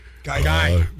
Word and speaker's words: Guy: Gay Fieri. – Guy.